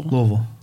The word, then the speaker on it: Louisville: So how do you say the name and say, is it Louisville? – Louisville.